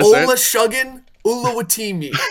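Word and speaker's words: Olusegun Oluwatimi: Try to Ola Shuggin Uluwatimi. That's how you say it.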